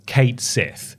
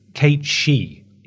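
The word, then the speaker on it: Cait Sith: For the longest time I thought it was Kate Sith. Apparently that's not right. It's Kate She. If you're pronouncing it the Irish way.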